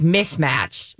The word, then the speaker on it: Ms. Match: I think it would be pronounced like mismatch.